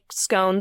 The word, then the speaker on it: Scone: Macduff confirms and says that Macbeth is already on the way to be crowned at Scone, which is spelled like scone the pastry, but pronounced Scone, and is an ancient royal city.